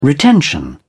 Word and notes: retention: British English

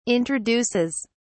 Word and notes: introduces: British English

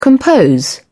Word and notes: compose: British English

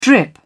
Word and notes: drip: British English

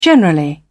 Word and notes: generally: British English

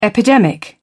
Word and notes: epidemic: British English

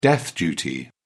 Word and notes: death duty: British English

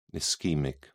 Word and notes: ischaemic: British English

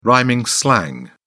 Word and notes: rhyming slang: British English

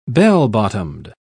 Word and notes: bell-bottomed: British English

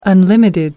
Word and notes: unlimited: American English

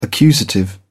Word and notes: accusative: British English

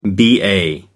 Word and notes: ba: American English

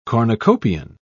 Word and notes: cornucopian: British English

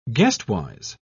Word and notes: guestwise: British English

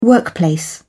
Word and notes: workplace: British English

